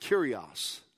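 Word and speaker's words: Kyrios (Κύριος): it's pronounced Kyrios, okay? And when you go down to verse 13, the word Lord there is Kyrios.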